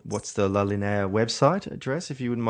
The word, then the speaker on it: La Linea: How do you pronounce it? La Linea.